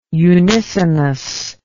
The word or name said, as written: unisonous